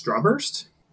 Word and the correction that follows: Starburst: Starburst? – Starburst, sorry, yes. I was pronouncing Strawburst, but oh, okay.